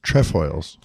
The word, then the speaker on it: Trefoils: Trefoils.